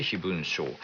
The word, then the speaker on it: 飛蚊症: 埼玉県 50 代のニャーゴさん、強度近視のせいか、飛蚊症、飛ぶ蚊、刺される蚊ですね、飛ぶ蚊の症状と書いて飛蚊症、 30 代から気になっています。定期検査では様子を見ながら慣れるしかないと言われていますが、年々悪化しているようで心配ですということで、この飛蚊症